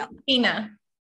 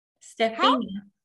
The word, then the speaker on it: Stephenia: Stephenia. – What? – Stephenia. – Stephenia? Mm-hmm. Stephenia. – Stephenia.